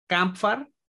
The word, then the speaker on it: Kampfar: Eh, la banda noruega de folk black metal, Kampfar. y voy a deletrear esto porque posiblemente no lo está pronunciando bien, K-A-M-P-F-A-R.